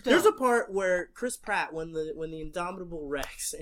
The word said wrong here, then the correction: Indominus Rex: Indominus Rex, excuse me, I mispronounced the fake dinosaur's name,